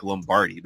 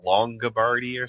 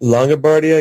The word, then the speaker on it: Longabardi: How do you say, I keep wanting to call him Mike Lombardi, but it's like Mike Longabardi or something like that. – Longabardi, I guess.